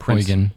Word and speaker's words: Prinz Eugen: I pronouncing that right? – Prinz Eugen. – Eugen. – Eugen is fine.